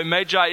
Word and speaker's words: Magi: By the way, Magi is the correct pronunciation, so that's why I'm using it with you, in case it sounds strange to you.